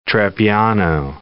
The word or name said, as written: trebbiano